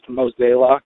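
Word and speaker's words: Mozeliak: First of all, the Cardinals president of baseball operations name's pronounced Mosellock.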